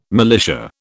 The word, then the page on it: militia: British English